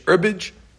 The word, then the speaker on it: herbage: Herbage, or I guess silent H, herbage.